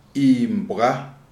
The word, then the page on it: Imbwa: Shona